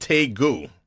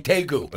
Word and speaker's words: tegu: It's pronounced Tegu. – Okay, Tegu.